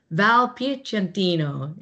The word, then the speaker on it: Piacentino: I want to say it right. Val Piacentino. Is that right? – Close. That's perfect. That sounds perfect.